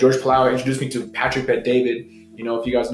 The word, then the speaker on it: Patrick Bet David: Patrick Bet David. – Patrick Bet David.